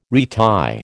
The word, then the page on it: retie: American English